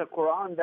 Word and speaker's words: Quran: Who is Muhammad, the recipient and proclaimer of the Quran? – I love how you pronounce it, Pat. That's the Quran.